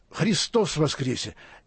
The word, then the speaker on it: Христос воскресе: И не случайно на Пасху в Русской Православной Церкви не говорят «Иисус воскрес», а говорят «Христос воскресе». И вот это вот ударение на «о» я не специалист фонетики, но это все преображает.